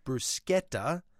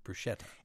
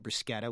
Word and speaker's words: bruschetta: And I am going to say it the way that an Italian friend of mine told me to say it, which is bruschetta. But in fact, many, of course, call it bruschetta. – And that's a really good example of how a word is anglicized. Those who speak Italian or are very familiar with Italian cuisine are likely to call it bruschetta, which is how it's pronounced in Italian.